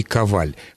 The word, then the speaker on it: Коваль: Ну, Елена Николаевна уже поставила ударение, причем правильно вообще, Юрий Коваль. Хотя совершенно нельзя исключить того, что существуют люди с такой же фамилией, но имеющие ударение на первом слоге.